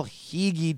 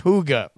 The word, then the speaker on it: hygge: Eating homemade cinnamon pastries, watching TV under a duvet, tea served in a china set, family get-togethers on Christmas, they're all Hege too. Oh, wait, hold on. It's a Danish word pronounced hygge.